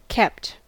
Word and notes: kept: American English

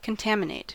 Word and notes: contaminate: American English

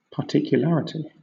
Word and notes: particularity: British English

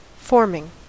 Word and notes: forming: American English

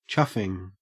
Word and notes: chuffing: Australian English

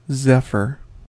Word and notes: zephyr: American English

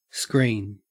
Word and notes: screen: Australian English

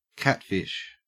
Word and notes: catfish: Australian English

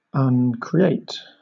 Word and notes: uncreate: British English

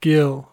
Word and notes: gill: American English

